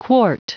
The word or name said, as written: quart